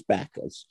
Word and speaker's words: Backhouse: It's written Backhouse, and we pronounced it rather appropriately as Bacchus.